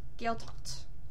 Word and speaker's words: Gaeltacht: They are called Gaeltacht. Did I say that right? – Yeah. – Or do not pronounce the C part? Gaeltacht or Gaeltacht? Or neither? – What was the difference? – I kind of said the C before the T. – Gaeltacht. – Gaeltacht. – Yeah.